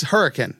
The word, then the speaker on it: Hurricane: Hurricane, West Virginia. It's Hurricane. That's how you pronounce it.